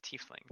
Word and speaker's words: tiefling: The correct pronunciation is tiefling, good sir.